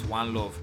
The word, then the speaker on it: Wanlov: And me personally, my own is One Love, but it's spelled WANLOV, One Love. There's no E at the end, so WANLOV.